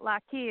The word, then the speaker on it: Lough Key: You did, yes, and that's the fun part about Lough Key is because we say it, you know, lock and key, you know, so that's how I always remembered it.